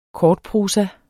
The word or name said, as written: KORTPROSA